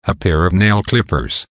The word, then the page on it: a pair of nail clippers: American English